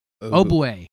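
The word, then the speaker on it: Ogbuehi: Cedric Oboey is actually how you say it. Oboey.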